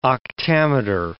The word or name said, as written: octameter